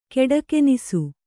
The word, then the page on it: ಕೆಡಕೆನಿಸು: keḍakenisu